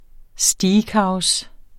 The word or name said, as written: steakhouse